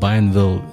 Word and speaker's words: Bienville: Correct me if I'm pronouncing it wrongly, but I suppose it's called Bienville Legacy Commission. Is that right? – Yeah, that's right. It's French. Bienville.